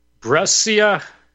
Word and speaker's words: Brescia: Okay, so then we had, let's see, February 4th, 2024, in Brescia, Italy.